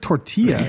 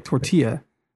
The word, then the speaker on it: tortilla: No, it's just tortilla. It would be LLA, like tortilla. There's no Rilla again, like Tortillarilla.